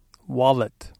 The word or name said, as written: wallet